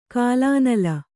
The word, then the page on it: ಕಲುನೆಲ: Kannada